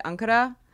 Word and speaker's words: Ankara: Well, they pronounce it Ankara. Okay. The Turkish, but my mom read it in a newspaper the day I was born. She's like, oh, Ankara, I like that.